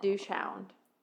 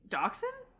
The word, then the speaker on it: dachshund: Is it that how you say it? – Dachshund? – That is not dachshund, is it? – Well, I don't know if...